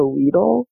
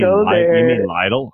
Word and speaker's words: Lidl: You mean Lidl? I call it Lidl. – You call it Lidl. Yeah, they pronounce it Lidl. So that's, I still call it that.